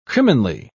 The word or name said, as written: criminally